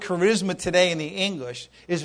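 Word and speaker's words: charisma (χάρισμα): It's charisma. That's how you pronounce it, charisma, and it means a gift of grace.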